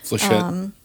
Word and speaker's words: flechette: You've got, like, I don't know how you pronounce it, Fletchet guns. – Flechette.